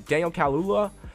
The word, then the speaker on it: Daniel Kaluuya: I finally said his name right, Daniel Kaluuya.